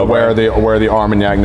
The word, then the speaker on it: Armagnac: We just I don't think me have ever seen on a bottle finish in, in a, hopefully I'm pronouncing it correctly, Armagnac? – Armagnac. Armagnac. Yeah.